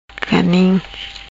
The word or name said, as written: Ganim